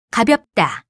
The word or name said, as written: 가볍다